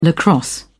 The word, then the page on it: lacrosse: British English